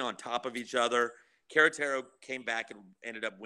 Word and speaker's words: Querétaro: Am I pronouncing that correctly? Is it Querétaro? – Querétaro. – Querétaro, sorry. Querétaro.